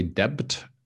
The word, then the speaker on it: debt: Okay, so a lot of people say debt, no B, debt. Okay, so silent B.